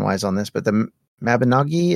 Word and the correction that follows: Mabinogion: is that how you say that? Mabinogian? – The Mabinogion. Well,